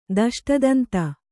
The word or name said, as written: ದಷ್ಟದಂತ